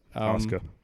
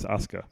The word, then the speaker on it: Asuka: Once, soon as it came down to the Bellas and Asuka, it was like, yeah, I think Asuka's going to win this. – Oscar. – Whatever. And is it Oscar? – It's not Oscar minor wiener, mate. It's Asuka.